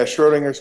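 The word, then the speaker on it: Schrödinger's: Actually, I want to touch on a dissertation you wrote, and I can't pronounce it, Schrodinger's? – Yeah, Schrodinger's crystal.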